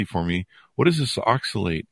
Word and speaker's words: oxalate: So this, did I pronounce it right? Oxalate? – Oxalate. Very good.